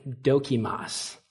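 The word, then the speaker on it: dokimos: And what it is it's the word dokimas. Let me give you how to spell it. It's D-O-K-I-M-O-S. Dokimas. It sounds like a Pokemon, I don't know.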